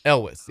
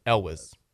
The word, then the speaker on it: Elwes: Elwes, yeah. – Elwes. – Okay, there you go. – But not L-S. – Elwes.